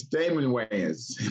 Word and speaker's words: Damon Wayans: Because you said, Isaac, you said Damon Wayans. – Oh, in my intro? I'm sure I butchered that. – It's Damon Wayans.